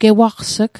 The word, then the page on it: gewaqs'g: Mi'gmaq